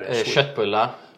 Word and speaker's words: Köttbullar: Köttbullar. – Köttbullar? – Yeah.